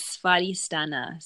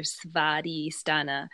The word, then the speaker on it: Svadhisthana: This one, the Sanskrit word for it is Svadhisthana. So the W is like a V, or Svadhisthana.